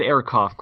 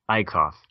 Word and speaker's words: Ekeroff: I get Ekeroff quite a bit, but it is just Ekeroff, just like Einstein.